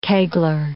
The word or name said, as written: kegler